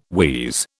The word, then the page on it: wheeze: British English